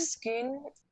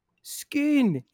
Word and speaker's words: Scone: How do we pronounce it properly? – Scone. – Scone. It's the Stone of Scone.